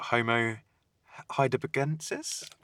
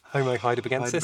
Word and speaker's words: Homo heidelbergensis: So he was, what, Homo heidelbergensis? – Well pronounced. Yeah. Just about.